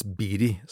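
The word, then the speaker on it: Beatty: Det skrives Betty, men det uttales Beery, samme som Warren Beery.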